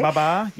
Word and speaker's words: Babar: The Pakistan captain, Babar Azam, I hope I'm saying that correctly. – Babar, yep.